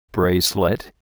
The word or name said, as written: bracelet